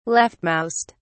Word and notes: leftmost: British English

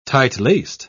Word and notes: tight-laced: British English

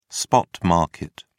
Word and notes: spot market: British English

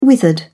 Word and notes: withered: British English

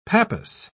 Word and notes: pappus: British English

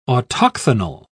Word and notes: autochthonal: British English

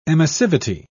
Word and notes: emissivity: British English

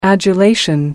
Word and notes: adulation: British English

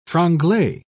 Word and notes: franglais: British English

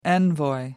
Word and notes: envoy: American English

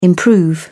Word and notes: improve: British English

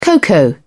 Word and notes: cocoa: British English

